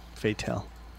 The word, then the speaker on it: Fatel: Fatel. You just went fatal, though.